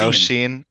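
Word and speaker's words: Róisín: how you say her name, huh? – Yeah, yeah, yeah, yeah. Roisin. Roisin. – Okay. – Not I learned Rosen. that today. – right, Roisin.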